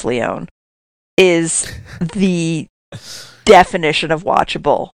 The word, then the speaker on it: Lyonne: Lyonne is the official pronunciation. – Thank you.